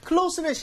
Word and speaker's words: close: 자, 지금 상훈 씨가 한 거는 마지막에 SE를 C처럼 발음한 겁니다. 그래서 CLOSE라는 이 단어는 형용사가 되면 close에 가깝게 다시 한번 보여드릴게요. close, close.